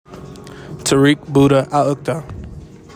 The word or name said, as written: TAARIQ "BUDDAH" AL-UQDAH